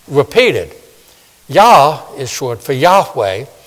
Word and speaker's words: Yahweh: Sometimes people pronounce it Jehovah, but in Hebrew it's Yahweh.